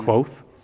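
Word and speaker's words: Kvothe: So his name was Quoth. I think that's how you pronounce it, right? Yeah. Quoth.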